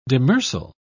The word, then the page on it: demersal: British English